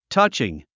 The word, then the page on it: touching: tʌ’tʃiŋ; タァチン